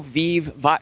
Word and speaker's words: Vive: All right, so we're both new Vive. Vive rhymes with Steve.